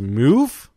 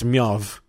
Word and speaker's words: MEOVV: So it's spelled M-E-O-V-V. So it has meow. – It looks like meow. Yeah, it does look like meow. It has this weird apostrophe thing on the E. I don't even know how to pronounce it. – Meow? – But people think it's pronounced as move. – Move. – To me, it looks like meow. Because of the double V, it looks like a W. – Yeah. – Unless it's Myov.